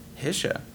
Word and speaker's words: Hisch: Well, Hisch. I mean, technically I pronounce it wrong, too. The Germans would probably sneer at me, but... – How would the Germans say it? – Hisha? – Yeah.